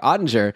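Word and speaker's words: Oettinger: Like, there's a chance that he's like Nettinger, you know, like, or sorry, I keep saying it wrong. Ottinger, like last year, Ottinger hadn't played much.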